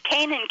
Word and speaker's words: Kanin: I said Kanin, K-A-N-I-N, and I thought you said Kissimmee. – Oh. – Meaning... – entertaining myself over here. – Yeah, that's the way you pronounce it. It's not like Kissimmee.